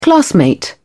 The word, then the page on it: classmate: British English